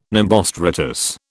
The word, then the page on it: nimbostratus: British English